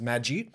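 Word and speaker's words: Magi: Depending on how you pronounce it, it depends. But I guess in English, pronunciation is Maggi, Kingdom and Labyrinth of Magic.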